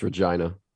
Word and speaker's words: Regina: It's Regina. – I already said it. – It's actually how it's pronounced.